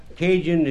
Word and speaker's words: Cajun: Cajun is a mutilation of the word Acadian. And you spell it generally C-A-J-U-N, pronounced Cajun in Mexico.